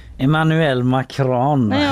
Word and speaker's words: Emmanuel Macron: Emmanuel Macron, vilket gött uttal du hade på den.